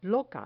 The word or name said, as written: loca